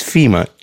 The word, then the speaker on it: Fima: My name is Fima, just like the organization, but spelled with an I.